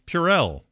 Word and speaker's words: Purell: Anyway, or Pure. Maybe I'm even pronouncing, it might be Pure wrong. – Somebody's going to put a... Purell. – No, yeah, no. Purell, exactly.